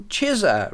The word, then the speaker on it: Chisa: a 1967 release in the US on Chisa Records. I think that's how it's pronounced, C-H-I-S-A, Chisa.